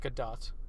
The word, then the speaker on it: Gadot: I found out that it's pronounced Gadot. – Gal Gadot? – Yeah. Just like I found out that it's pronounced Jared Leto.